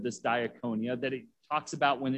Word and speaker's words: diakonia: When it says they were being neglected in their daily distribution, this word for distribution is this first word, diakonia. It's spelled D-I-A-K-O-N-I-A.